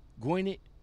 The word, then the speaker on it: Gwinnett: on I-80 in Gwinnett. Am I pronouncing that right? Gwinnett County, G-W-I-N-N-E-T-T, Gwinnett. Yeah, Gwinnett.